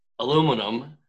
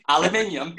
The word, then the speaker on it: aluminium: We were talking to you, and you pronounce aluminum aluminium. – Right, as it should be, aluminium.